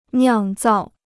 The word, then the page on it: 酿造: niàng zào